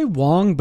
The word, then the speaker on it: Wang: Wang or Wong? Wang. – They say Wong, but it's spelled Wang, and yeah.